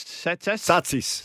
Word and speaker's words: Tsatas: Elijah Satsis. From Oakley Chargers. – Can you pronounce that again? How do you spell his surname? – T-S-A-T-A-S. Satsis? – Satsis.